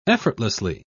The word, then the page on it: effortlessly: British English